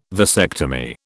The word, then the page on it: vasectomy: British English